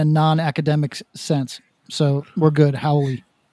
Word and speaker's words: haole: So the term is Howley. – Howley. – Yeah. – Howley. But it's spelled H-A-O-L-E.